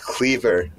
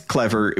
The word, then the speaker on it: Clever: uh, i didn't really, i thought it was a pr- pronounced cleaver not clever it's not your cleaver it's there, there's been there's been some controversy about that but it's it's um it's it is clever.